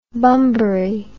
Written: bunbury

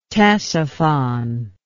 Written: ctesiphon

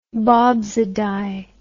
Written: bobsydie

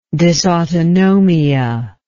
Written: dysautonomic

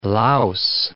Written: louse

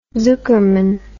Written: zukerman

